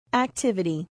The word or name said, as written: activity